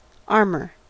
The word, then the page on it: armour: American English